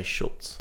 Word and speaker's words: Schulz: It's written Schulz, but they say Schultz. – So it goes LZ yes. – at the end. I think with German language, Z is pronounced as if it's TS, so it's Schultz.